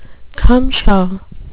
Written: cumshaw

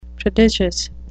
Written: prodigious

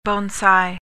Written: bonsai

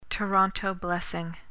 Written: toronto blessing

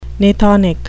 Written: gnathonic

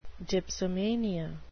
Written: dipsomania

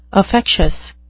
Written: affectious

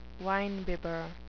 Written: winebibber

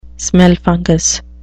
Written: smellfungus

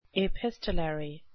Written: epistolary

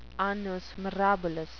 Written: annus mirabilis

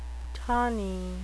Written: tawny

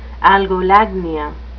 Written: algolagnia

